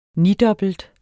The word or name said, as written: nidobbelt